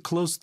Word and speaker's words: close: 동사로 쓰였고 the hotel is close to the airport. 이때 발음 close예요.